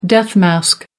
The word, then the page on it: death-mask: American English